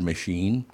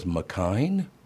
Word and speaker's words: machine: Oh, she's trying to pronounce the word machine. And they say, okay, how do you say this? M-A-C-H-I-N-E. She goes, McKine? – Oh, no.